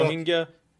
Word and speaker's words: Rohingya: We've heard about the Rohingya. Am I pronouncing that correctly? – Yeah, Rohingya in Myanmar, the Uyghur Muslims in China.